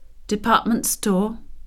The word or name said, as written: department store